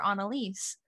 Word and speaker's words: Annelies: It's like, you're not renting, you're Annelies.